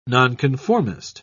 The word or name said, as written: nonconformist